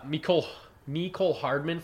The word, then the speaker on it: Mecole Hardman: Anyone else on, I thought Mecole Hardman from Georgia, 4-3-3.